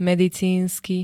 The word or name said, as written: medicínsky